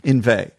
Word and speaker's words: inveigh: What's the word you're using? Inveigh? – Inveigh. Inveigh against. – How do you spell it? – I-N-V-E-I-G-H. Inveigh.